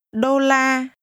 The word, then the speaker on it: Đô la: Đô la. – La. Đô. Đô la. Đô la. – This is pronounced with two mid level tones.